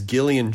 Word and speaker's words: Gillian: This episode is Gillian Jacobs, who, by the way, Will Wheaton, it pronounces the G the correct way, hard G. No shade to any soft G Jillians out there, like my sister-in-law, who's a Jillian. But Gillian, hard G, just want to drive it home for the Wheaton, all right? Hard G Gillian, soft J Jacobs.